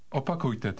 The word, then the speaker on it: Opakujte: Opakujte to. Opakujte to. – Here's the word repeat, starting from the end of the word. – Kujte. Kujte. Opa. Opa. Opakujte. Opakujte.